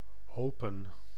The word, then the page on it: hopen: /ˈɦoː.pə(n)/; /hɔʊpə/